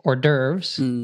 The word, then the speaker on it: hors d'oeuvres: Well, I'm curious how you guys would say this, because Ollie said it with French pronunciation, but I think most natives don't know how to do the nice French pronunciation. So we'd probably say, like, in the States, hors d'oeuvres, but you guys would probably say that quite differently with the R's.